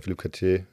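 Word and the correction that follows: Philippe Chatrier: Philipp Chatrier.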